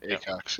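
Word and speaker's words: Aycox: Next up, Supernatural actor Nikki, and forgive me if I mispronounce this, Acox, I think. – That's right. – Acox, yeah.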